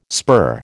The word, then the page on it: spur: American English